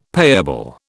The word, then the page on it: payable: British English